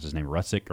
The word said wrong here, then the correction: Ruzic: or Ruzik. I can't. Carter, you may know that better than I do. Steven Rutzik. – Ruzic. – Yeah.